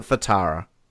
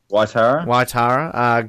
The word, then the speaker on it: Waitara: Waitara, or is it Fatara? – Waitara.